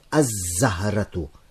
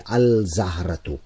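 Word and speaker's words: az-zahratu: It would be wrong for me to say Al-Zaharatu because Za is a sun letter and if a word begins with a sun letter then then I cannot pronounce the lam. I cannot say al zahratu. I must say az zahratu.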